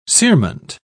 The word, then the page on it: cerement: British English